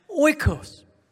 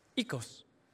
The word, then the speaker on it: Oikos: Now, depending on where you are in the world today, you'll pronounce this either as Oikos, or actually, if you go to Greece right now, they pronounce it Ikos. Ecos, or here in the West, oikos. No matter how you pronounce this, some even pronounce it oikos.